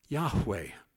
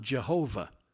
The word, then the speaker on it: Yahweh: His name is not Jehovah. There is no J or V in the Hebrew alphabet. It is impossible for an Israelite to pronounce his name Jehovah. We pronounce it that way because of the influence of the German language on our English language. But no Hebrew would ever think to call that Jehovah.